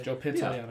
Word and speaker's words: Pantoliano: Joe Pantoliano, am I saying that right? – Joe Pantoliano. Yeah. Yep.